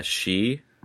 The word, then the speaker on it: sidhe: S-I with a little dash over it, D-H-E. The Celtic language, everybody. – Is it actually pronounced she, like woman? – No, that's actually how it's pronounced. Like the word banshee, if you actually spell it the Gaelic way, it's B-E-A-N space S-I-D-H-E.